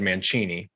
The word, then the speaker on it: Mancini: I mean, if we were like when we went to Italy, everyone said Mancini, which is how you'd pronounce it. But at this point, we've been in America long enough. It's been Americanized.